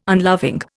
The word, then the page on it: unloving: Indian